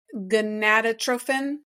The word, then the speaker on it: gonadotropin: So that's the human chorionic gonadotropin.